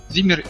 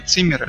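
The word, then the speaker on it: Zimmer: Зиммер или Циммер, это, ну, зависит, как произносить.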